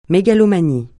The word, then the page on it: mégalomanie: francais